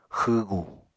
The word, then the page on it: xwwgu: Apatani